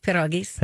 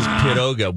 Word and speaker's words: pierogi: Pierogies. – Pierogies. Pierogies. – There you go. You said it right the first time, Mike. – Nobody says pierogi. – Everybody says pierogi. Nobody says piroga.